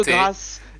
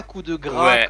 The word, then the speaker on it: Coup de Grâce: De grâce, et non pas coup de gras, comme ces deux abrutis de collègues qui n'en démordent pas.